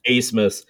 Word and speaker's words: Abmas: For Texas, A-B-M-A-S, and his name is pronounced Asemus. – Yeah. – Their leading scorer, who had a 20-point game against us, Max Asemus, was absolutely held in check.